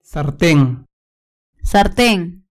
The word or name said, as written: sarten